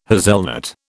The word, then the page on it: hazelnut: British English